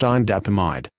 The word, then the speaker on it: indapamide: This is the consumer medicine information for Matrilix, indapamide, pronounced indapamide.